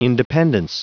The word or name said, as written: independence